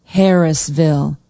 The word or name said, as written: harrisville